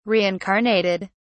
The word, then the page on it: reincarnated: British English